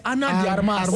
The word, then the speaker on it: Ana de Armas: We know that you're Ana Amas Amas. – Don't even spoil it. – You say Ana Amas Amas. – What? Pronounce that name where Ana de Armas.